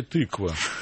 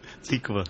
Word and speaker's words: Тиква: Я правильно говорю? Тиква, да. Или тыква? – Тиква.